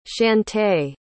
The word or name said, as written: chantae